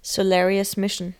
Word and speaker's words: Solarius Mission: Solarius Mission. – Wichtig, dass man es englisch ausspricht, hat uns der Autor nochmal mehrmals betont. – Ja, hat der Mike extra gesagt, ja. – Es wird nicht deutsch ausgesprochen.